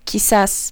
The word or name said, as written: quizás